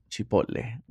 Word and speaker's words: Chipotle: Chipotle. Well, I could say it any different way. I could say no. – Chipotle or Chipotle.